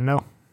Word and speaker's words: Arduino: Yeah, definitely Arduino.